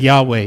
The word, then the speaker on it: Yahweh: And it was changed to these four letters, YHWH, which we pronounce it as Yahweh.